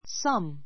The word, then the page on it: some: 弱形 s(ə)m; サム; 強形 sʌ́m